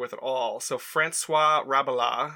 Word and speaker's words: Rabelais: I have always said Rabelais and heard Rabelais,